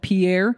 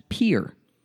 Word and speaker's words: Pierre: The capital of South Dakota, it looks like it's spelled Pierre. And most people from outside of the state call it Pierre, but it's actually pronounced Pierre.